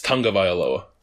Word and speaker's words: Tagovailoa: The letter G is pronounced like NG. It's Tagovailoa.